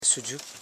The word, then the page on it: sudgu: su.dgu